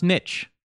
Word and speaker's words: niche: If you look it up in the goddamn dictionary, American dictionary, it is actually pronounced niche.